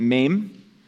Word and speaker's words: Mem: We pronounce it Mem.